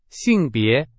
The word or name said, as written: 性别